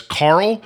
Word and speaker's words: Karel: Anyway, next person I have here is Elijah Cook Jr. as Carl, but it's spelled K-A-R-E-L.